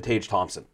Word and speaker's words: Tage Thompson: They lost to Tage Thompson.